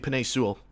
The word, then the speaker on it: Penei Sewell: But I think the Jets, if they are comfortable with Darnold, they are going to probably draft the left tackle Selway, if I'm pronouncing Penei Sewell. That's how you pronounce it.